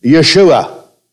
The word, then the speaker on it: Yeshua: The Jew today would pronounce it Yeshua. That would be the Hebrew pronunciation.